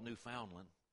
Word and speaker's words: Newfoundland: I call it Newfoundland. That's just the way it's pronounced here.